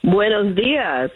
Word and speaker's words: Buenos dias: Buenos dias. You pronounced it very well in Espanol.